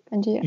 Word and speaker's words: Pangea: Am I pronouncing it correctly? Pangea. – You are.